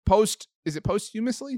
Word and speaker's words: posthumously: The infallible Ron Rivera fired defensive line coach Sam Mills, who is the son of the Sam Mills who was posthumously? Is that how you pronounce it, that word? – Posthumously enshrined in the Pro Football Hall of Fame last weekend.